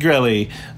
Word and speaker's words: Grelli: But we'll be right back, continue talking about Monongahela-style rye whiskey with Meredith Grilly. Am I pronouncing that right, Meredith? – Yeah, rhymes with smelly. – All right, Meredith Grilly from Wigle Whiskey.